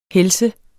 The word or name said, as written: helse